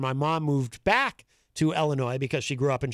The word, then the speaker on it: Illinois: Illinois is a great place. I spent a lot of time there when I was a kid. – Illinois? – No, the S is silent, my friend.